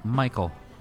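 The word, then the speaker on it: Michel: Michael. – Okay. – It's M-I-C-H-E-L.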